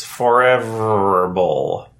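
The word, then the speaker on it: Forevr Bowl: But it's spelled for, like, Forevr. So it's Forever Bowl.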